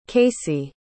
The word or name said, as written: casie